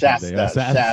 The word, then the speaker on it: Shasta: Shasta.